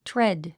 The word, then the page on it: tread: tréd; トレェッド